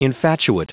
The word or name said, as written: infatuate